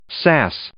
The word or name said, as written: sass